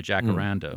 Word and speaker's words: Jacaranda: Jacaranda.